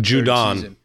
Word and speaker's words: Judon: He's in like his fucking Judon.